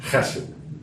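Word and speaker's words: chesed (חֶסֶד): It has a guttural sound to it, chesed. It's pronounced chesed.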